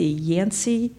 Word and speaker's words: Yancey: I'm here with Ed Brooks, and he is the director of the Yancey School Community Center.